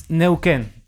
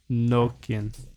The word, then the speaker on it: Neuquén: How do you say it? – It's Neuquén. – Yeah. Neuquén.